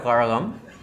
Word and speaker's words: Kazhagam: Just one little piece of news, the Dravida Munnetra Kazhagam, because I'm half Tamil, I can say Kazhagam. – Is he pronouncing it right? – Yes, he is.